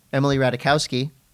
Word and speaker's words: Ratajkowski: Emily Ratajkowski and Jonah Hill, two A-list Hollywood celebrities, banded together to save one of the only dual genre restaurants in Encinitas.